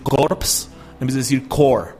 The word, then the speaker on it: Corps: Y la palabra core, aunque se escribe a corpse, se pronuncia core.